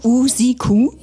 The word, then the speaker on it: Usiku: Usiku? – Ja, perfekt. Also wenn man zweimal U sieht am Anfang und Ende, die muss man auch lang sagen.